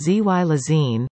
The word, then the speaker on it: xylazine: Xylazine, pronounced Z-Y-Lazine, is a tranquilizer for large animals, like horses and cows, not for use in humans.